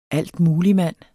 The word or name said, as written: altmuligmand